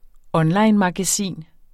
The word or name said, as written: onlinemagasin